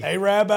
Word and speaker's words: Arab: A-R-A-B. – Arab, Alabama. – Arab, Alabama. – It's right before Lake Gunnersville. Coach Wild knows a great catfish restaurant on Lake Gunnersville. But yeah, Arab, Alabama. – Long A.